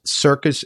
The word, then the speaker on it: Cirkus: It's spelt C-I-R-K-U-S, Circus in Sweden, Pritz, and it's P-R-U-T-Z. But if you just look up C-I-R-K-U-S, I'm pretty sure you'll get Circus Pritz coming up.